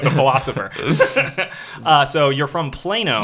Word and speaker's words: Plano: Did you know them out in Plato or Plato? How do I pronounce that? – Plato? – Plano. – Plano. – Is that it? – Plano. – Plano. Sorry about that.